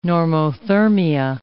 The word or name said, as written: normothermia